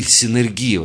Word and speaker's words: синергия: Именно вот быть сотворцом Богу, а именно так вот оно считается, что сотворец Богу, синергия называется, или синергия, вот правильное ударение,